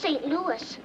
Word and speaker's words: St. Louis: Excuse me, Mr. Neely, but it's pronounced St. Louis. – Is it now? I got a cousin spells it the same way, and we call him Louis.